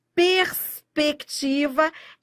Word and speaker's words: perspectiva: Perspectiva é dígrafo ou digrama? O R é pronunciado? É. O S é pronunciado? É só você falar perspectiva. Tudo pronunciado.